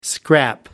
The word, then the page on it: scrap: American English